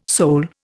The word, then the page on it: soul: Indian